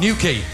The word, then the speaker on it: Newquay: And how do you pronounce it? – Newquay. – That's it.